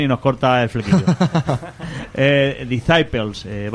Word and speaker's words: Disciples: No sé si lo digo bien, Disciples. – Disciples, sí.